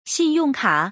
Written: xin4yong4ka3

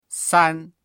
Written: san1

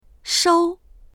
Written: shou1

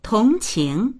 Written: tong2qing2

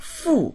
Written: fu4

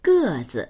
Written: ge4zi

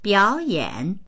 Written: biao3yan3

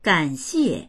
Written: gan3xie4

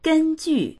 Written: gen1ju4

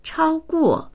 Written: chao1guo4

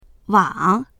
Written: wang3

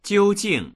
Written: jiu1jing4

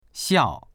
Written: xiao4

